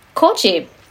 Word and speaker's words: koji: It's its semi-official national mold, and that is koji. I'm actually really impressed you said it correctly.